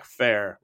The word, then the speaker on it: Fair: Where he met Cloud Strife, the protagonist of Final Fantasy VII, and Zork Four, psych Zack Fair rhymes with O. contraire.